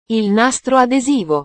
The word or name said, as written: il nastro adesivo